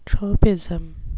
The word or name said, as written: tropism